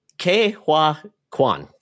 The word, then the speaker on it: Ke Huy Quan: K Juan Ke Huy Quan.